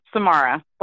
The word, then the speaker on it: Samara: That's right. Samara. The creepy girl from The Ring.